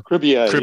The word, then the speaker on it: Cribier: and Alain Cribier. Am I saying his name correctly? – Cribier, yes.